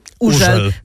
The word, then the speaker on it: Hugel: I właśnie to jest ta ciekawostka, bo jego nazwy się czyta, pisze Hugel, a czyta się Urzel. To jest bardzo ważne, bo często miałam zwracaną uwagę, że robię to źle.